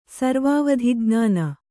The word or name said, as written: ಸರ್ವಾವಧಿಜ್ಞಾನ